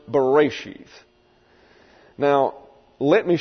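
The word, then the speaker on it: Bereshith (בְּרֵאשִׁית): Bereshith is the way it's pronounced.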